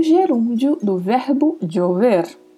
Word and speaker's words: llover: E a letra V, que tem leve som de B. O E é bem fechado e o R com aquele vibrato que já comentamos várias vezes. Então, umas possíveis pronúncias seriam llover, jover jover chover.